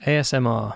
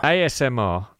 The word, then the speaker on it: ASMR: I can never pronounce it. AMSR? – ASMR. – ASMR.